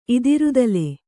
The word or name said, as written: ಇದಿರುದಲೆ